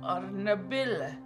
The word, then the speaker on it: Arnabil: Arnabil. Arnabil. Not Otto. Arnabil. – Oh. – Arnabil. – It's the rolling R. – Yeah, you got to get the elder roll.